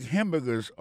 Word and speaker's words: hamburger: N'a pas manqué que dans mot hamburger, ça a comme tu as dit, il y a trois syllabes là-dedans. Quand tu appuies et puis plus ça, c'est sous premier syllabe là.